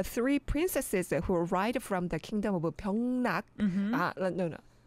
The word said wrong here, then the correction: Pyeongnang-guk: I think I mispronounced it. Pyeongnang-guk.